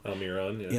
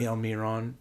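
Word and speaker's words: Almirón: Almiron. – Almiron.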